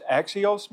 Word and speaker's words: axios: In Revelation 4:11, the Greek word translated as worthy, is it pronounced axios, Marsh? Yeah, close enough, isn't it?